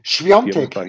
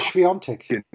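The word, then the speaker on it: Świątek: Schwiontek, Iga Schwiontek.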